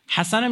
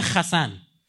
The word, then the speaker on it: حسن: یا بعضیاشون انقدر احمقان چون اونا تو زبان خودشون تو یهودیای اشکنازی ه و خد تلفظ میکنن، حسن میگه خسن،